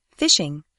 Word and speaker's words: phishing: First of all, we should explain that even though the pronunciation is the same, this variety of phishing is spelt with a PH and not an F.